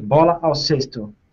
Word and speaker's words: Bola ao cesto: So how do you pronounce it again? Bolo o Cesto? – Yes, that's it. Bola ao cesto.